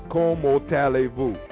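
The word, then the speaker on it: Comment allez-vous: So it's not silent, but it's not said as it's read. So you wouldn't say comment. Allez-vous is como tallez-vous.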